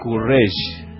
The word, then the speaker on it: Courrèges: Mira cómo tiemblan mis pies cuando estás, y le nombré mal el apellido de vuelta, pensaba así. Pierre Courrèges es el apellido, así se pronuncia.